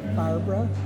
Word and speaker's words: Barbara: Barbara Feldy, F-E-L-D-E. – Can you spell your first name? – B-A-R-B-A-R-A.